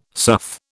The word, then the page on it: sough: British English